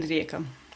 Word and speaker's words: Rijeka: Yes, and one in Rijeka. – One in Rijeka. I avoided attempting to pronounce that town's name until I'd heard you say it.